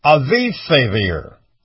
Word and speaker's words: Avifavir: Now, the other thing that's going on here is the Russian Health Ministry has approved an anti-coronavirus drug called Avifavir.